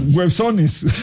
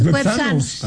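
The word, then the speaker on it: güepsano: Websones. – No, lo dijo bien Andrés. Websanos.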